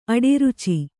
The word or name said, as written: ಅಡರಿಚು